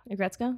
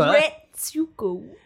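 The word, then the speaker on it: Aggretsuko: How do you say it? Agretsuko? – Yeah, just a little faster.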